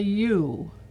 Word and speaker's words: ewe: It's called a ewe. It's not spelled with just a U, but it's spelled, it says U, E-W-E.